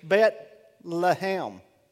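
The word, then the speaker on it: Bet-lehem (בית לחם): Did you know that when you look at Bethlehem in the Hebrew, Bethlehem was Bet-lehem, Bet-lehem.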